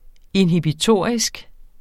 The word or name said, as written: inhibitorisk